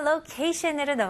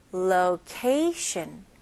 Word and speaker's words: location: 다음 단어 location으로 넘어가겠습니다. 네, location은 장소라는 뜻을 잊지만 좀 더 위치에 가까운 단어입니다. 네, 발음 연습부터 해볼게요. Location, location. Okay, 같이 해볼게요. Location. 마지막 T-I-O-N 발음은 S-H-U-N으로 한번 해볼게요. Location.